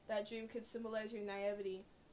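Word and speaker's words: naïveté: Naivete. It's like Nevea. The name Nevea. Heaven backwards.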